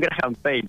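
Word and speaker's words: Graham-Paige: ¿Ya tenían ahí el, auto de 1928, 29, este gran este autazo que se llama, es un Graham Page, ¿digo bien? – Sí, es un Graham Page, lo pronunciaste perfecto.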